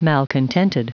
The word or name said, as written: malcontented